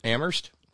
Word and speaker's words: Amherst: Thank you, Colin and Ralphs and Rusty and Terry in Amherst, where only the H is silent.